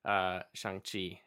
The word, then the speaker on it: Shang-Chi: I got to go and see Shang-Chi and the Legend of Ten Rings.